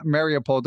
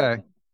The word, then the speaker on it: Mariupol: You're from Mariupol. Did I say that right? – Yes, from okay. – Mariupol.